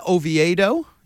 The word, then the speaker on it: Oviedo: Johan Oviedo, and I checked it during the break. We are saying it right.